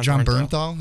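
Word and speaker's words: Jon Bernthal: I love John Bernthal. Is that, am I pronouncing that right? – Yeah, you got it right.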